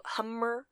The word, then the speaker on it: Humr: It's spelled H-U-M-R, so hummer, hum.